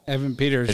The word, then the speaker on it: Evan Peters: Evan Peters.